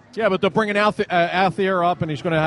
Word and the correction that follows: Altier: You mean Altier? – Altier, yes.